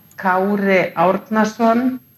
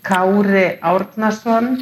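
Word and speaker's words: Kári Árnason: Kauri Arnason. Kauri Arnason.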